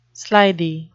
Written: slithy

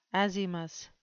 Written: azymous

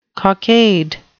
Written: cockade

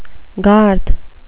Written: garth

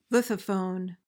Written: lithophone

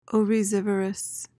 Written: oryzivorous

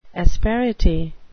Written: asperity

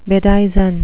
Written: bedizen

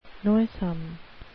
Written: noisome

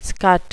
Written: scut

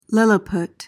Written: lilliput

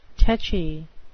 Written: tetchy

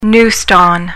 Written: neuston

